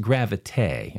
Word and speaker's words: Gravité: All right, there is a commercial on TV called Gravité, spelled like gravity, but it's pronounced Gravité, and it's a cologne for men.